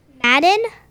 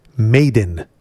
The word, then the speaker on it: Madden: Madden. – That's correct. Wow. – I, I think it's pronounced Maiden. Maiden. Nope. you are mistaken again.